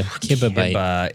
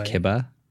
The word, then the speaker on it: kibibyte: So yeah, kibibyte. – It sounds small. – It's K-I-B-I, if that helps. – Kibibyte. Oh. – Kibibite. – Kibibite. – But it's pronounced kibba. – I've heard it said both ways.